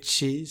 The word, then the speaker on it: Qi: Ja, det är Qi som i Tai Chi.